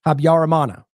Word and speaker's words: Habyarimana: That is how you pronounce that, Habyarimana.